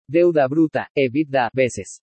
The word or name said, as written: DEUDA BRUTA/EBITDA VECES